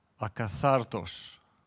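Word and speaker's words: akathartos (ἀκάθαρτος): If we look at the Greek, the word unclean is pronounced akathartos.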